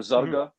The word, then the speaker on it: Zarqa: called Zarqa or Zarga, like it's Great. – pronounced with a G,